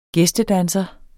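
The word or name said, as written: GÆSTEDANSER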